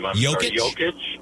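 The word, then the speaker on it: Jokić: Jokic? – Yeah, you got it.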